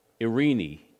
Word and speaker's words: Eirene: That word peace that we use there is pronounced in Greek, Eirene, E-I-R-I-N-I.